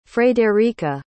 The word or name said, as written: fredericka